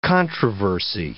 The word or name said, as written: controversy